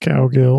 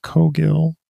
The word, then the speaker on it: Cogill: Our guest today is John Cogill, not Cowgill, although I do make a joke coming up in the episode about fish and cow emoji. That's not how it's pronounced. It's pronounced Cogill.